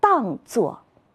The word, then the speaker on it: 当作: 同学们一定发现了，在这些词语当中还有一个多音字，当作。课文里是这样写的：山脚下有一堵石崖，崖上有一道缝，寒号鸟就把这道缝当作自己的窝。在这里，它的读音读四声，它的另外一个读音读一声，可以组词：当然、当心、每当。